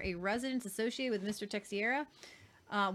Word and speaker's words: Teixeira: We cannot pronounce his last name. Teixeira. – Teixeira.